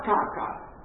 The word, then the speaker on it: KK: Nell'Austria asburgica, scrive Musil, tutto era, e lo spiega, imperial regio, Kaiser König, abbreviato in KK, che si pronuncia Kaka.